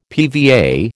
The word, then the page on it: PVA: American English